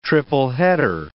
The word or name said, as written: triple-header